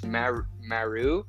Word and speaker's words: Maru: But it's Maru, I think is how you pronounce it, M-A-R-U.